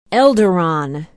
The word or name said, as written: elderon